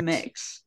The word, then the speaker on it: Mx: And how do you say that? It's mix? – I think MX. It's mix. Yeah, I think it's mix.